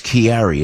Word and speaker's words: Chiari: And how do you say that's Chiari? Is that the correct pronunciation with the, yes. Yes.